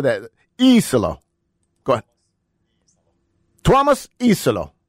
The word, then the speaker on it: Iisalo: So obviously the spelling of his last name is Iisalo. So it seems to me like you have a... – He has a long I when he says it.